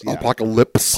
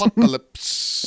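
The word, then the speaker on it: Apokolips: Apocalypse. – Apocalypse.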